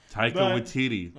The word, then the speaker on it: Taika Waititi: I hope that Taika Waititi. I don't know if I think we're both saying it wrong. – Taika Waititi. That's what his name is.